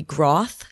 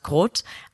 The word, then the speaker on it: Groth: We just recently learned on an episode that we have been pronouncing your name wrong all of these years. The term that we're familiar with would be Groth 16, a very important proving system that's used by many projects. But we just learned that it's actually pronounced Groth and maybe even like Groth 16.